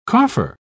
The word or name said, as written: coffer